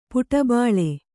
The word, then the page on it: ಪುಟಬಾಳೆ: kannada